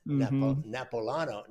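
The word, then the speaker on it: Napolitano: They don't pronounce the I. The I is not pronounced according to the guy that's married to his cousin and somebody else mentioned it. I pronounced it wrong. Napoletano. – Yeah. – It's not, you don't pronounce that I. Not Napoletano. It's Napoletano. – Wow. – Said like one syllable. One guy said, I've never heard that name pronounced in three syllables before.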